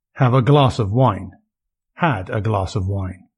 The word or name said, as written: have a glass of wine